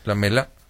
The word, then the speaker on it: Lamela: El caso de Eric Lamela, el jugador argentino. – La importancia de la sílaba tónica, ¿verdad? – ¿Por qué? – No, Eric Lamela, ¿no? Se ha pedido así. – Sí, Lamela.